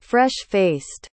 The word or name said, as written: fresh-faced